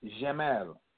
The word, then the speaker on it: Jamal: My name, my middle name, is in Arabic is pronounced Jamal. Same root, J-M-L, as far as the consonants.